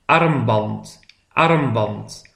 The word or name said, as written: de armband